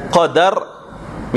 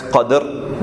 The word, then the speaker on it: Qadr: Firstly, the term Qadr, The term Qadr can be pronounced in two ways. One is that you can pronounce it as Qadr with a fatha over the dal, Qadr, Qadr.